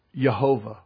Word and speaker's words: Jehovah: They pronounce his name Jehovah, and it's written in the New World Translation instead of Lord, L-O-R-D, capitalized. They write Jehovah, but I pronounce it Yehovah. They're okay with that, but it still catches them off guard. And so I pronounce the J as a Y because it would be in Hebrew.